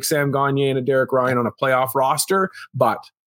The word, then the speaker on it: Derek Ryan: Say his name right. It's Derek Ryan. – Derek Ryan.